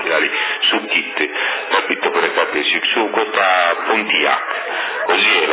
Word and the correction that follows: Pontiac: Pontiac, non Pontiac, Pontiac, con l'accento sulla O, Pontiac.